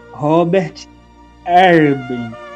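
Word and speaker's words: Robert Herbin: Morre Robert Erben, lendário técnico do saint Etienne. Falei certo, Rael? – É isso mesmo.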